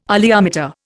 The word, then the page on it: oleometer: Indian